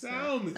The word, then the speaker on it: salmon: You could say, I'm down for salmon. Did I say the L? – You don't pronounce salmon.